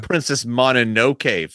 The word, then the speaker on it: Princess Mononoke: I've heard stuff about this little Princess Mononoke film. – Yeah. – Am I saying that right? – Yeah. Then, jeez.